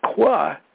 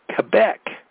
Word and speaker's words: Quebec: And Quebec, it's not Qua, it's Quebec.